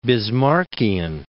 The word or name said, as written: Bismarckian